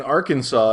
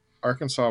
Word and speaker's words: Arkansas: When I was doing research for this episode, I saw that at one point, Arkansas was actually, and maybe it was river, was spelled A-R-K-A-N-S-A-W. – Yes. – Yeah. – And then they kind of standardized the spelling with Kansas, but didn't change the pronunciation at the same time.